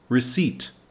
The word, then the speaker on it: receipt: Number two is receipt. R E C E I P T be careful, the P is silent, so we say receipt.